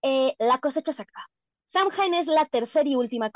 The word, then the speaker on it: Samhain: Igual también muchas personas pronuncian Sowin, eh, yo pronuncio Samhain.